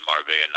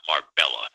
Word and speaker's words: Marbella: So, by the way, there's two L's, and it's pronounced Marbella, not Marbella.